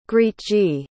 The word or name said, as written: grietje